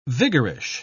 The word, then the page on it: vigorish: British English